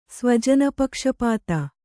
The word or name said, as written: ಸ್ವಜನಪಕ್ಷಪಾತ